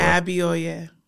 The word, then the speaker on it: Abioye: Abioye.